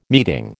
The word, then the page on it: meeting: American English